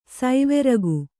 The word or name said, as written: ಸೈವೆರಗು